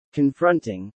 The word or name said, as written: confronting